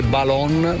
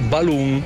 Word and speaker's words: balloon: Vorrei solo correggere, la pronuncia non è balon, ma è balloon.